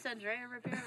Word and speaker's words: Andrea Ribeiro: Pretty close, Andrea Ribeiro.